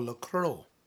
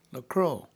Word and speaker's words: LaCroix: It's called Lacroix. L-A-C-R-O-U-X, I think it's spelled that way. Lacroix.